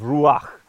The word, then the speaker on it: ruach: In fact, the word in Hebrew is ruach. It's kind of like a, you have this sound in the back of your throat. So you sort of, that's how you say it, sort of ruach.